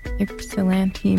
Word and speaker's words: Ypsilanti: Ypsilanti, Michigan.